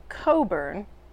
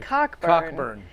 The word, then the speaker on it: Cockburn: Her real last name is pronounced Coburn, but it looks like Cockburn.